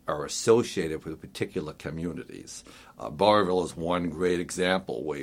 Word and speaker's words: Burrillville: So many times you'll hear people drop the two middle L's and just say Burrillville, and it's actually Burrillville.